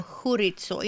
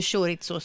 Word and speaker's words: chorizo: Och så uttalar vi då chorizo, inte chorizo som jag.